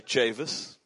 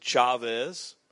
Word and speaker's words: Chavis: I think it's pretty simple to say Chavis. But Chavis is not a Hispanic name. So oftentimes people try to pronounce it Chavez. But Chavis is a Native American name.